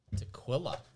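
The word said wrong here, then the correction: Tahlequah: How would you say that? – Taliqua. – Taliqua.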